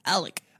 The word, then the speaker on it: Alex: Alex. A-L-E-X. But everyone says Alec.